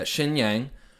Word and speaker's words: Xinjiang: Yeah, yeah, Xinjiang, the province.